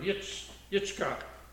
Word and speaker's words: Yitzchak: Isaac is kind of in Hebrew, it doesn't even really sound the same, but it's the same word, Yitzchak.